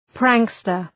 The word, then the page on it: prankster: ‘præŋkstər